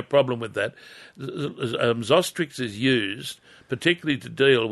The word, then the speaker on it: Zostrix: Now, there's a little product called Zostrix. I'll spell it for you. – Yep. – Z-O-S-T-R-I-X, Zostrix. – Z-O-S-T-R-I-X, Zostrix.